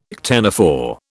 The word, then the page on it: ctenophore: British English